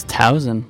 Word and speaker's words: Towsen: Or do people say Towsen or Towsen? – It's Towsen, like it's a Z even. – Why did I just say it like a goddamn monster? – Because the word toe is spelled T-O-W.